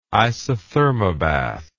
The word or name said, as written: isothermobath